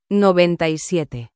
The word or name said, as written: 97